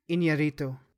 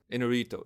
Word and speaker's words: Iñárritu: He's Alejandro Iñárritu. – Iñárritu.